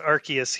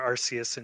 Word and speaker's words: Arceus: It depends on what like region you're in. It's Arceus here, Arceus in Japan.